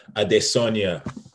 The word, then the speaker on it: Adesanya: Anyway, people, we got a fucking awesome fight this weekend between Robert Whitaker and Israel Adesanya.